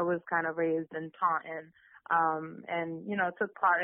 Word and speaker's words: Taunton: Is that how you say it? – Yeah, Taunton, yep.